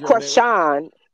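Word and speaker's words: Chrisean: Is the girl, how you pronounce it? It's Krishan. – It's Krishan. That's what I thought it was.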